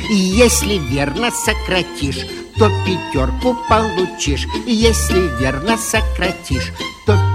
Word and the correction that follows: получишь: Николай Владимирович, нельзя говорить «получишь». Надо говорить «получишь». Вы неправильное ударение ставите.